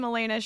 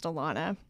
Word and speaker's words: Alaina: No, the French spelling of Alan is Alain, A-L-A-I-N. So it's pronounced Alon, and then putting the A on the end is kind of like a bastardization of that name.